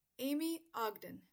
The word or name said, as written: Aimee Ogden